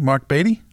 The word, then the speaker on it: Marc Beattie: Mark Beatty? – It is.